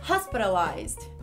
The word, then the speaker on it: hospitalized: Uma palavra que muita gente pronuncia errado é a palavra hospitalizado em inglês, que se pronuncia em uma respirada só, da seguinte forma: hospitalized. Mais uma vez, hospitalized.